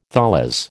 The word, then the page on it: tholos: American English